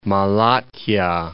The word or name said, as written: Malatya